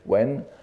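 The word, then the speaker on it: wen (文): enfin le caractère chinois bun, n'est-ce pas qu'on prononce en chinois actuel wen, mais cela ne nous concerne pas ici.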